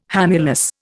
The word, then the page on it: hamulus: Indian